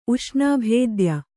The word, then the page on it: ಉಷ್ಣಾಭೇದ್ಯ: kannada